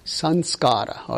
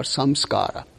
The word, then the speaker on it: samskara: It's called, every impression is called a sanskara or samskara, right? Okay? So it depends. The pronunciation varies. If you are from the south of India, they call it samskara. From where I am, we call it sanskara.